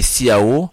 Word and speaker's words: ciao: Ciao s'écrit avec C-I-A-O. C'est écrit Ciao, mais on lit Ciao. C-I-A-O, c'est Ciao, ça se prononce. Quand même Q-U-A-N-D, espace M-E accent circonflexe M-E, tout de suite. C'est T-O-U-T, plus loin D-E, plus loin S-U-I-T.